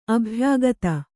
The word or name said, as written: ಅಭಿಯೋಕ್ತೆ